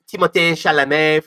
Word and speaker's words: Timothée Chalamet: It also has Timothée Chalamet from Call Me By Your Name, which is cool. – I do like that pronunciation. – That is the actual pronunciation.